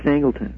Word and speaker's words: Angleton: How do you pronounce that word, name, Angleton? – Yes, Angleton.